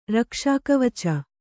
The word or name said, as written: ರಕ್ಷಾಕವಚ